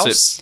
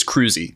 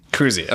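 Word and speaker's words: Kruse: No, it's K-R-U-S-E. And it's, I think, the way you actually pronounce Krause? – It. No, it's because they did they had a pronunciation guide in his son's obituary. – Oh. – So I'm pretty sure it's Cruzy.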